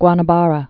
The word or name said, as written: Guanabara Bay